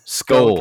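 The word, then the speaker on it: Schull: Skull.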